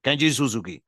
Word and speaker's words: Kenji Suzuki: Kenji Suzuki, así se pronuncia en japonés.